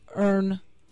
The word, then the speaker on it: Erne: Earn, E-R-N-E, Resort.